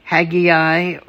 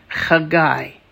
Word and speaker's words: Chagai: That means, that is often pronounced in English as Haggai or Haggai, but in Hebrew it's a much more beautiful sound. It's Chagai.